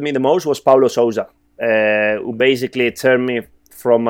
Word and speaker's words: Sousa: Sousa, yeah.